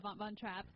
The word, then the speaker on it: Von Trapp: Like, if you saw the Von Trapp, how do you pronounce that? – Yeah, Von Trapp.